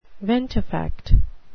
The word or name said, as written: ventifact